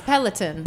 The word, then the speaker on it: Peloton: How do you say it? Peloton, the bike? – Yes, Peloton.